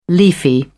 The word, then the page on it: leafy: British English